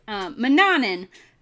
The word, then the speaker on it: Manannan: Manannan, that's how you say it.